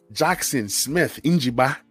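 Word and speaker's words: Jaxon Smith-Njigba: So at number six, I want you guys to listen to the pronunciation of this name. – Oh, here we go. – Number six, give me my brother from another mother. My brother from the motherland, Jackson Smith Njigba. Okay, that's how you say his name.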